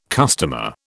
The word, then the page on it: customer: British English